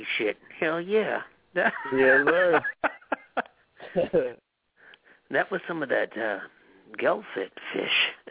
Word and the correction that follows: gefilte fish: Am I pronouncing that right? Okay, what is a Gilfit fish, first of all? – It's a gefilte fish.